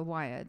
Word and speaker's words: Weir: Yeah, so Weir, that's how you pronounce it.